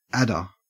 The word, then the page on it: adder: Australian English